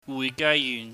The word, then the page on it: wuihgaiyuhn: cantonese